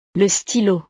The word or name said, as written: le stylo